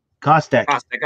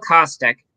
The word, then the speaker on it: Kostek: Kostek. – Okay, thank you. Camille Kostek.